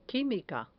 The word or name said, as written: quimica